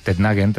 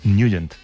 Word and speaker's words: Nugent: Hombre, siempre lo hemos llamado en el barrio Tednagent o Tednagent. – Claro, yo era de Tednagent. – Pero la pronunciación correcta es Nugent. – Te fuiste a Washington y descubriste que era Nugent.